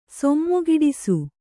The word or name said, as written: ಸೊಮ್ಮುಗಿಡಿಸು